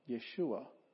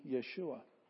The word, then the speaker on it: Yeshua: And so by the time Jesus came and lived among us, the name had been shortened to Yeshua. Yeshua. And to this day, and over here, the capital letters, that's the accented syllable.